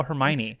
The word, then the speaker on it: Hermione: I mean, this was before the movies, too, so it was, I vividly remember all of us reading it as a class and people mispronouncing Hermione. My whole class was called her Hermione for like two straight years until the movie trailer came out. Like, oh, Hermione.